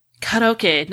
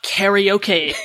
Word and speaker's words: karaoke: And by the way, people, it's called karaoke, not karaoke, the way the Americans say that.